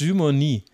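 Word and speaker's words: Dumornay: Dumonet habe ich sie genannt und es ist Dumoni wenn ich es jetzt richtig mir gemerkt habe.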